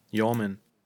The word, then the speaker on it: yeoman: Each, wie, wie wird das ausgesprochen? – Yeoman. – Each Yeoman äh, will be cast aside.